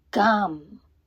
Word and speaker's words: GAM: GAM, GAM, GAM. It sounds like gum, but with an R.